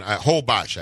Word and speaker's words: Holbox: It's Isla Holbox. So it's an Holbox, actually, is how they pronounce it. But it's an island. – If for people, Holbox? – Holbox.